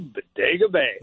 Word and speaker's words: Bodega Bay: Greetings from beautiful Bodega Bay.